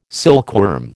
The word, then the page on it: silkworm: American English